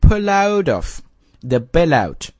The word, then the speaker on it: pull out of the bailout: 啊，这个地方稍微提一下它的读音啊，有好几个连读，pull out of the bailout， OK， pull out， pull out of the bailout，啊，这几个元这个连续的连读，OK。